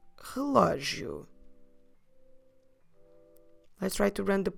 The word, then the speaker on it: relógio: relógio. Relógio. The stress is on the lo, because we have an accent there. Como se escreve relógio? R-E-L-O. Com acento. G-I-O. Relógio.